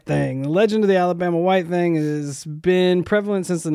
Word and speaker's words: Alabama White Thang: And I am not mispronouncing this. The Alabama White Thang. THANG.